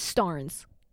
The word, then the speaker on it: Starnes: But my last name is Starnes, spelled like Barnes.